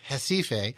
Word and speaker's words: Recife: And that just for folks, so it's spelled R-E-C-I-F-E. Looks like recipe with an F, but it's pronounced Recife.